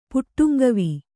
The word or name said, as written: ಪುಟ್ಟುಂಗವಿ